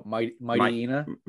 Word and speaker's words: Mightyena: Oh, Mightyena. – Mightyena.